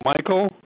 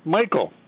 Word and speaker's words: Michael: Oh, Michael Buck. B-U-C-K. – Spell the first name, please. – Pardon me? – Spell your first name, please. – I tell you, Michael. – Yes, can you spell it for me, sir? – M-I-C-H-A-E-L.